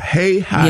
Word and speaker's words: Hahira: All long, long A, long I. Hahira.